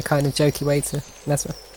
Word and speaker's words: Ymezra: Uh, Mesra, uh, which, by the way, is spelt Y M E Z R A.